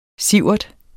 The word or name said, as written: sievert